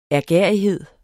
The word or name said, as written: ærgerrighed